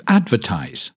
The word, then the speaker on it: advertise: Then we have the verb to advertise. This word has three syllables, and the stress is on the first syllable.